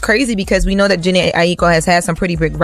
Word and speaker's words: Jhené Aiko: So, Jhené Aiko.